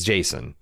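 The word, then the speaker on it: Jacen: But I also think they're undercutting themselves with Sabine and Jaqen. Or Jason? It's Jason. I always pronounce it Jaqen when I was reading it growing up, but I guess it's Jason. Sabine appears to be in her late teens,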